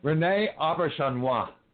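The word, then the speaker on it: René Auberjonois: Rene Auberjonois.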